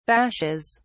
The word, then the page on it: bashes: British English